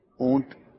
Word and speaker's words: اونٹ: اچھا, ایک تو اس کا تلفظ بھی درست کر لیا جائے. کئی لوگ جو ہیں وہ اونٹ ٹھے کے ساتھ پروناؤنس کرتے ہیں, تو یہ ٹھے نہیں بلکہ ٹے ہے, تو اس کو ہم اونٹ کہیں گے.